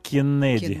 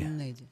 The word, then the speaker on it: Кеннеди: Кеннеди. – Кеннеди. А ну. – Боб Кеннеди. 59 год. Вообще, Кеннеди фамилия тогда не очень-то, наверное, и звучала.